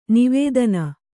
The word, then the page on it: ನಿವೇದನೆ: nivēdane